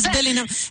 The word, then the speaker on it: Bellingham: Ya quedamos que es Bellingham.